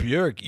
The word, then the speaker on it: Burek: I know we have a guest today, a great guest for episode 7, which is Stacy Burek, did I say it right? – Burek, you did, Stacy Burek.